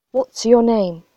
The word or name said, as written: what's your name